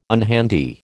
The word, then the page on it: unhandy: American English